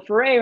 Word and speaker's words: Foray: Yes, well, foray rhymes with hooray, so there you yes.